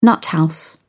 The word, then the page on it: nuthouse: British English